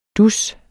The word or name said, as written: dus